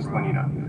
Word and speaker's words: Slanina: And if I were famous, I would want everyone to know that I was Ron Slanina. But I do recognize that it's difficult for people. In California, everybody says Salinas, and it's the easiest name. It's Slanina.